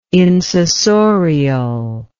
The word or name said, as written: insessorial